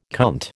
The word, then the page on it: cunt: American English